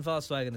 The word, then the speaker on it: Volkswagen: The V in Volkswagen is more pronounced like F in English.